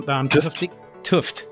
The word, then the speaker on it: Tufte: Tufte.